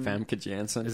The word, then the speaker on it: Famke Janssen: Or the one, I don't know how you pronounce her name, Famke Janssen? – Famke Janssen.